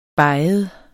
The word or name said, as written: beget